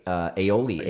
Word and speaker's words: aioli: And the I'm going to butcher this, I think, but the aioli. – Yes, oh, I got it right.